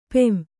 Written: ಪುಂ

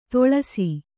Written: ತೊಳಸಿ

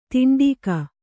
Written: ತುಂಡುಗೈ

